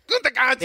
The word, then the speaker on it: Gutekunst: Gutekunst.